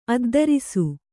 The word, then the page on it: ಅದ್ದರಿಸು: Kannada